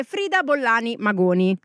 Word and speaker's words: Frida Bollani Magoni: Lei è Frida Bollani Magoni, giusto? Ho detto giusto? – Sì, hai detto giusto.